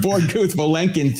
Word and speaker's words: Borde-Guth-Vilenkin: And you've quoted many times the Gord or the I always get the name wrong. – The Gord Vilenkin. – Yeah. The Bord-Guth Vilenkin theorem.